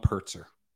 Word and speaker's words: Purtzer: The person's name is Tom Pertzer. – Tom Pertzer. – Yeah. – Spell that. – P-U-R-T-Z-E-R.